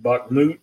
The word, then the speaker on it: Bakhmut: I'm not real sure what has happened in, is it Bakhmut? Is that how you say that? – That's how I've heard it pronounced, yeah.